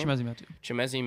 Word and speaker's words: Chimezie: Chimezie Metu.